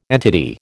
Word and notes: entity: American English

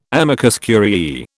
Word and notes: amicus curiae: British English

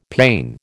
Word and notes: pane: American English

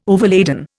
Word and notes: overladen: Indian

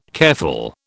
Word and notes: careful: British English

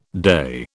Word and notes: day: British English

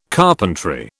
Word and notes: carpentry: British English

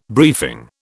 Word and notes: briefing: British English

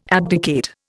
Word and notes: abdicate: Indian

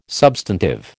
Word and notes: substantive: American English

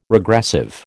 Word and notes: regressive: American English

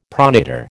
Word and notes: pronator: American English

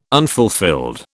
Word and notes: unfulfilled: British English

